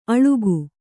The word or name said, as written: ಅಳುಕು